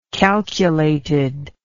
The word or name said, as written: calculatedness